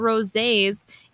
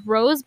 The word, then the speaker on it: rosé: Yeah, so the interesting facts about rosés, it is French. That's why it's spelled like rose but pronounced rosé.